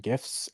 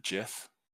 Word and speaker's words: GIF: So, I've been working on that and gifts, and I will be for the next couple weeks. So, that's me. – So, you pronunciate it GIF instead of JIF? – Yes.